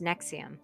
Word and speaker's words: NXIVM: So she, well, and it's spelled weird. It's NXIVM. – Yeah. – And it's pronounced Nexium.